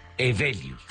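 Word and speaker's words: Heweliusz: Sí, muchas, muy complicado porque es polaco, pero se pronuncia Hevelius.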